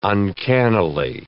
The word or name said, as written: uncannily